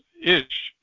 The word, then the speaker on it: ich: Like the word for I is ish. To me, it's ish, I-C-H, ish.